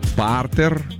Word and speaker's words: партер: Так, а как же все-таки правильно говорить? «Партер» или «партер»? Куда «Партер». – Ставить? «Партер».